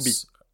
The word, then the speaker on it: Kubi: Kubi, Kubi, je sais pas comment on prononce. – Kubi. – Kubi, mm.